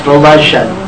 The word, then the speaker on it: Volozhin: V-O-L-O-Z-H-I-N. – Volozhin. – That's pronounced Volozhin. – Okay. Volozhin. Thank you.